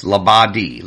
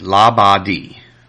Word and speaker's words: Labadie: Yes, Labadie, Labadie. It's French, and no accent on any syllable.